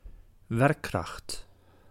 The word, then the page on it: werkkracht: /ˈʋɛr.krɑxt/